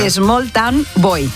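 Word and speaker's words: Smalltown Boy: El primero fue ese maravilloso Small Town Bow. Small Town Bow. Hoy voy, hoy se lo digo bien. – A ver. – Small Town Boy.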